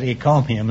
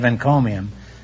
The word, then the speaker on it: encomium: For some curious reason, I said ecomium instead of encomium.